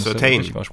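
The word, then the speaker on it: Surtain: wie spricht man nicht? Sutton? – Certain. – Certain, okay. Entschuldigung.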